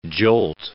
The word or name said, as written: jolt